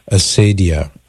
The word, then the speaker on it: acedia: It's called acedia, A-C-E-D-I-A. Well, some people pronounce it the more the Latin way, acedia.